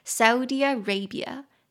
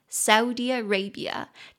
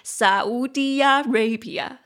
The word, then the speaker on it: Saudi Arabia: 所以其实沙烏地阿拉伯啊，说实在话，这是我第一次正式学到它正确的发音，因为它的发音是这样子的嘛 Saudi Arabia, Saudi Arabia, 但是我以前因为被中文影响，我一直以为它念起来是 Saudi Arabia, Saudi Arabia, 不要再多念几次好了，我怕扰乱听众朋友，就跟我扰乱自己一样。